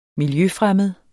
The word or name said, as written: miljøfremmed